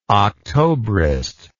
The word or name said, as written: octobrist